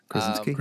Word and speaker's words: Krasinski: I hope I don't butcher his last name because I'm awful with names, but John Krasinski. – Krasinski.